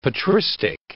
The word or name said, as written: patristic